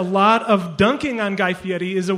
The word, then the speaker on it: Fieri: It's Guy Fieri.